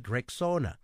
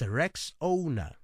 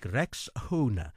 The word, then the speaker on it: Rexona: A friend even called it Rexona. But guys, it's Rexona, like Rexona, Rexona. This